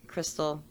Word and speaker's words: Crystel: So you can go to my website, Crystal.